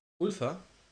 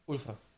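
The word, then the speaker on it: Ulver: Ulfa. – Das Ulfa ausgesprochen? – Ulfa. – Okay. Also, ich glaube, norwegisch Wölfe.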